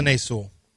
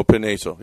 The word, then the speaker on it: Penei Sewell: Yeah, Penei Sewell. – Oh, Penei Sewell.